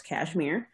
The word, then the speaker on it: KSHMR: It's spelled Kshmr and pronounced Kashmir.